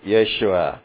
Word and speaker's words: Yeshua: Understand that the word salvation here is Yeshua. That is the ancient pronunciation of the word salvation. It is the Hebrew name that in modern translation gets pronounced Yeshua.